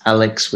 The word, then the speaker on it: Aleks: It's Aleks with a K-S, so A-L-E-K-S. Then my surname, Svetski, S-V-E-T-S-K-I.